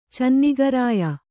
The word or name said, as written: ಚೆನ್ನಿಗರಾಯ